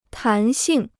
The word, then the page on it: 弹性: tán xìng